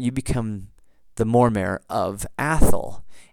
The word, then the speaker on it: Atholl: A-T-H-O-L-L, Athol, in case the exact pronunciation of that does not come through very well in this microphone and you think I'm saying something else. That's why I spelled it for you.